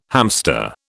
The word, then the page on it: hamster: British English